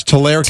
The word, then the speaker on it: Tulare: Is it Tulare or Tulare, California? – Oh, there it is. – Tulare, okay. California. You see that? – I do. – Tulare. It's Tulare, California. And I want to thank Gene in Cicero.